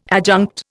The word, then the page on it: adjunct: Indian